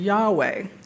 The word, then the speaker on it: Yahweh: We pronounce that word Yahweh, although we don't know how it was pronounced originally.